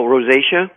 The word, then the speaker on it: rosacea: Almost like rosea, right? Is that how it's pronounced, Doc? Rosea? – Well, rosacea. – Rosacea, is that how it's pronounced? – Yeah, it could be rosacea.